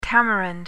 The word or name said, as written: tamarind